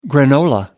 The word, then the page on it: granola: American English